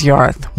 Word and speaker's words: Yorwarth: Now, the way we say our last name is Yorath, which is, as far as we knew, was the true way to pronounce such a name. So my last name is spelled Y-O-R-W-A-R-T-H.